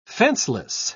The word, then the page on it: fenceless: British English